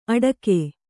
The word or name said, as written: ಅಡಕೆ